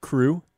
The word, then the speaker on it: CHRU: Did you know the Carolina Harm Reduction Union, known as CHRU, pronounced CRU, is a student-led program that provides life-saving harm reduction resources to the public of Chapel Hill.